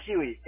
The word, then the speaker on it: Huey: Huey, H-U-E-Y, like the Huey helicopter.